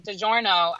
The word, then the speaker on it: DiGiorno: They, like, saw people putting DiGiorno. I don't know if I'm pronouncing that right. – Yeah, you were.